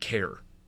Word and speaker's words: Kerr: Is it Kerr or Care? Former 7-Eleven employee to Braves postseason star. Because I had a buddy in high school whose name was K-E-R-R, but he pronounced it Care.